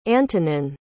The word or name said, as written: antonin